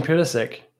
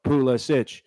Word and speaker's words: Pulisic: Christian Pulisic. – There we go. Pulisic.